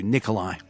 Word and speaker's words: Mikolajew: His name is spelled M-I-K-O-L-A-J-E-W, Nicolai.